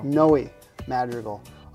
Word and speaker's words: Noe Madrigal: Noe Madrigal of A&A Roofing.